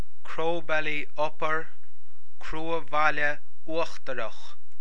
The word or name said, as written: Cruabhaile Uachtarach